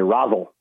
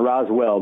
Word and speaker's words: Roswell: They say Rosal, not Roswell, but Rosal.